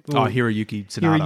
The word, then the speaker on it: Hiroyuki Sanada: Hiroyuki Sanada.